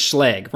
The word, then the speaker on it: Schlage: It's Schlage, rhymes with leg.